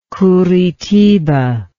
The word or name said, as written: curitiba